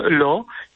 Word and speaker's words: Laus: Sanktuarium, nasze sanktuarium to jest sanktuarium Lo, pisze się Laus.